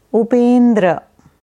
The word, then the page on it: Upendra: Sanskrit